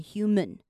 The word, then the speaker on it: human: 那 of 后面我们来看到 human 这个字，我们看到那个 man，我们会觉得是 man，但其实这边呢要念成 human，这边大家要抓一下那个感觉。